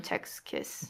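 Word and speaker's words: Sechs Kies: But in Korea, we call them Jex Kiss.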